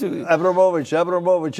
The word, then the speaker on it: Abramowicz: You don't mind if I give you the Polish pronunciation? – No, that's in Chicago. – Of course, that's... – Abramowicz.